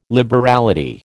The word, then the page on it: liberality: American English